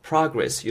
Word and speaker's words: progress: 그것도 잘 챙겨 놓으시고 약간 명사로 발음이 될 때는 progress 이렇게 돼야 된다는 것도 알아 놓으시면 되겠습니다.